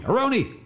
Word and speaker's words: Aroney: My conversation with Nancy Slonim Aroney, you might have, Aroney, that's the way to pronounce it.